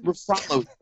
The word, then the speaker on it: Ruffalo: Ruffalo puts the emphasis on the second syllable.